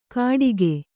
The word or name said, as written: ಘಟಿಗೆ